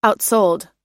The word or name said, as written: outsold